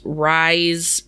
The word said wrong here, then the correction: Rhys: Bowen, R H Y S. Oh, Reese. – Um, maybe Reese. Reese. Reese Bowen.